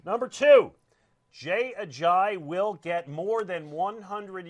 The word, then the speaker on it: Ajayi: But Jay Ajayi. I always forget the third syllable. – Okay. – Thank you. Jay Ajayi.